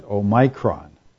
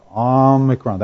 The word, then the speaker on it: Omicron: I've heard one guy calling it Omicron. Oh, that just grates. You know, I know the Greek alphabet. It's a short O sound, Omicron. That's where the emphasis is, Omicron. Omicron is a short O sound. Some people say Omicron. No, bothers me.